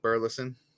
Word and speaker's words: Burleson: Alec Alec Burleson.